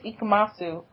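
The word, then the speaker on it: Ikumatsu: Right, like, even when he meets Ikemasu, oh my god, I said it right.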